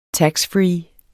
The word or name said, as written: taxfree